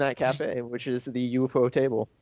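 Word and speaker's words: ufotable: UFO table. – That's how it's pronounced. It's UFO table.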